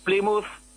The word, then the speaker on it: Plymouth: Escucha, ¿cómo se pronuncia? Porque yo he oído que se dice Plymouth y Bournemouth. – Es Plymouth, Bournemouth y Portsmouth.